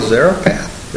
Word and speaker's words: Zarephath: But it also parallels in this position with the woman, the widow Zarephath.